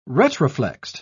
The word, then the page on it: retroflexed: British English